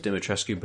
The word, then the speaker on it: Dimitrescu: Everyone, and again, I thought it was Dimitrescu, but it's Dimitrescu as everybody pronounces it kind of in the game.